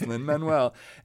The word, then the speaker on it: Lin-Manuel: Saying, yeah, he's like, everybody says Lin-Manuel, but it's Lin-Manuel.